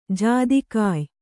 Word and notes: ಜಾದಿಕಾಯ್: kannada

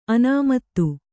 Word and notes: ಅನಾಮತ್ತು: Kannada

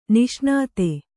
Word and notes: ನಿಷ್ಣಾತೆ: Kannada; niṣṇāte

